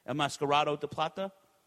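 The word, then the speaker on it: El Enmascarado de Plata: El Mascaradón de Plata. Did I say that right? El Mascarado de Plata? – There you go.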